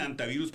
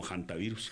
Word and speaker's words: hantavirus: Bueno, unos dicen antivirus porque la H es muda. Pero sí, en medicina, sí. Pues nosotros la pronunciamos siempre como hantavirus. – Hantavirus. – Porque acuérdense que incluso la H es muda. Pero es hanta.